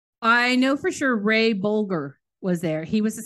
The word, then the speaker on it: Bolger: Bolger.